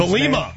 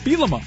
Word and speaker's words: Bielema: I wrote down B-Lima, like Jose Lima. – Belima.